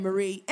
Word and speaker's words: Marie: As and always, I am Marie, M-A-R-I-E. – I'm Sydney. – Spell it. – S-Y-D-N-E-E, with apostrophe over the E.